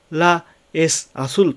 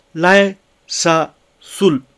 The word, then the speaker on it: la es azul: La es azul なのですが、それを La es azul と発音しないで、 Les azul. Les azul.